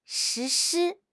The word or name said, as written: 实施